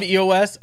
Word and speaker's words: EOS: It basically stops most of the Android privacy issues and tracking with the true open source mobile OS. – I love EOS. I love the idea of EOS. EOS. I can't. The /OS is just not a great. – No, sorry. You mispronounced it. It's /E/OS.